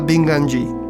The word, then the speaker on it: binganji: L'ocra bianca, chiamata binganji, si pronuncia binganji, è simbolo dello spirito.